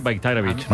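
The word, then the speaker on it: Bajraktarević: O sea, la J la conviertes en una I, Bayraktarevic.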